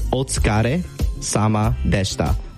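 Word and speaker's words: otsukare sama deshita (お疲れ様でした): The way to pronounce it is otsukare. So otsukare, otsukare, otsukare, and then sama, otsukare sama, otsukare sama, and then there's deshita, deshita, deshita. So altogether, it's otsukare sama deshita.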